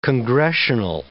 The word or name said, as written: congressional